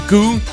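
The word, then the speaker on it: Gou: Peggy, og jeg har altid sagt Gau, men det er faktisk forkert. Nu er jeg sådan lige ved at læse lidt på det. Hun skal udtales Peggy Goo her i selskab med Ira Starr.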